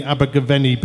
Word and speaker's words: Abergavenny: We are live from Campervan Campout right here in the Abergavenny building.